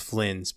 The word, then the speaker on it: Phlins: And a third I wanted to highlight, I feel like I'm going to butcher their name here, but it's G-O-L-Y-T, Gollit. I'm sorry, Gollit is the drop. The artist is Flins, P-H-L-I-N-S.